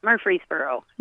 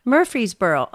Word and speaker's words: Murfreesboro: Tell me how to exactly say your town. I want to pronounce it perfectly. – Murfreesboro. – Murfreesboro. Okay, I came close.